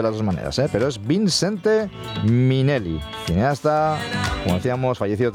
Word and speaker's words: Vincente: Lo he dicho bien, Vincente. Yo también creía que era Vicente, o Vicente al menos. Pues no, hay una N ahí de por medio.